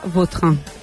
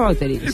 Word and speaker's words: Vautrin: La original francesa, Vautrin. – Vautrin. Ahí pega un Bauterín. A mí me gusta Bauterín.